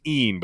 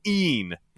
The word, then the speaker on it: Ian: It's pronounced Ean, but it's spelled I-A-N. So it's like Ian? No, Ean.